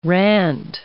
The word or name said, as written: rant